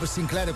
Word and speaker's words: Sinclar: capolavoro del maestro Bob Sinclar, o Bob Sinclair come vogliamo pronunciarlo, perché si può fare in tutti e due modi.